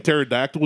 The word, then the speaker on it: pterodactyl: It's basically means T is silent, and I said pterodactyl, where the T is definitely not silent. – No. The P is silent.